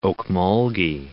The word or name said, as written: Ocmulgee